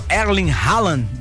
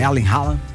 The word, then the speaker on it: Erling Haaland: Tem o Erling Haaland, da Noruega. – Bom, boa pronúncia, hein? – Gostou? – Gostei. – Erling Haaland.